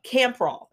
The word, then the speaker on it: Campral: Campral.